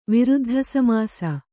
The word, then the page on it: ವಿರುದ್ಧಸಮಾಸ: Kannada